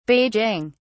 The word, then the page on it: beijing: British English